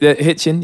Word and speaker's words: Hitchin: Hitchin.